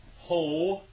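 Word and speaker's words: ho (ὁ): Nominative singular masculine. Ho, theos, ho logos. It's ho, isn't it?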